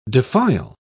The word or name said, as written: defile